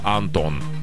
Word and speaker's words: Антон: Фамилия была Антон. – Антонина Антон? – А ударение. Антон.